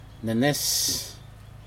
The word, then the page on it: nenes: Aneityum